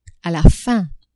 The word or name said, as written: a la fin